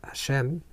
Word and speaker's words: Hashem: And the Yud Kei Vav Kei, the Yud, the Hei, and the Vav, the Hei, which is pronounced as Hashem, as the name, because we don't have an exact way of pronouncing this name, this name represents the transcendence.